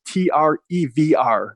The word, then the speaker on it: Trevr: It's Trevr.